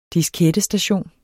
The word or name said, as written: diskettestation